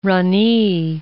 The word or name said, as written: rani